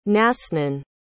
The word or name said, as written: nasnan